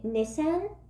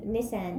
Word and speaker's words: Nissan: Well, also, is it pronounced Nissan? That Orlando Bloom kept saying Nissan. – Yeah. – But I work for Nissan. – But Nissan? I've always said Nissan.